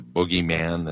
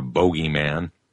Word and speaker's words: bogeyman: your parents may have told you that there's a boogeyman that's going to get you, or a bogeyman. It's actually the proper pronunciation.